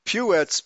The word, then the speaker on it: Puetz: Puetz, P-U-E-T-Z, is a variation. It's a spelling trying to accommodate the umlaut in Germanic, which would be pronounced Putz, U with an umlaut. And so it's spelled P-U-E-T-Z to try to accommodate the umlaut sound, okay? And there's also Butts, B-U-T-T-S, is also derived from the Germanic word Putz, okay?